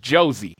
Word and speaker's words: Jose: No, and just to clear, it's Jose. I don't know why. I don't know why, but they pronounce the J in Brazil, so it's Jose Aldo.